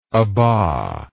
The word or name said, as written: abba